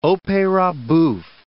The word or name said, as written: opera bouffe